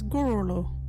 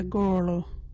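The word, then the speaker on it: gurulhu: Our local Wadjuti word is Gooralu. What is a Gooralu?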